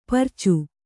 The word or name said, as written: ಪರಚು